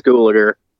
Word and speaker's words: Gulager: Clue Gulager. Gulager? Gulager? – I think it's Gulager. – Gulager, that's what I thought.